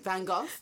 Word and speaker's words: Van Gogh: Van Gogh. That's how you pronounce it, apparently. I went to the museum. Van Gogh. – Van Gogh? – Yeah, apparently that's how you pronounce it properly in Dutch.